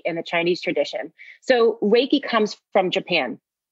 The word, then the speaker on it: Reiki: It's broken down to two syllables, Reiki, and Rei, I want you to think of Rei like the sun.